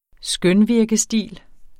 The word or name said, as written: skønvirkestil